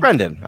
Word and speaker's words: Brendan: Speaking of dinosaurs, not long ago we spoke to the amazing Brendan Steer from, is it Brendan or Brandon? Did I spell that wrong? – Brendan. I like Brendan. Brendan sounds better.